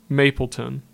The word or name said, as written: Mapleton